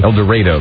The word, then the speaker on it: El Dorado: El Dorado, they pronounce it in Kansas.